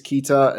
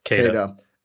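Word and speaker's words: Queta: 3 of them were Nemeas Keita and I think I said that wrong. Sorry. – Keita.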